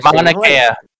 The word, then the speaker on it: Mauna Kea: It's on Moana Kea. You know, it's funny. They're Mauna Kea. You pronounce it Mauna Kea.